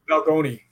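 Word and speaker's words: Balgonie: I want to talk a little bit about the Balgony. Am I saying that correctly? – Balgony, yeah.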